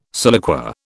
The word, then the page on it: siliqua: British English